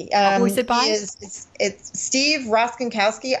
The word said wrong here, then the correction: Rochinski: R O C H I N C K I. I think okay. it's no. I'm sorry, Steve Rochinski. Gosh, okay. please don't tell him I mispronounced his name.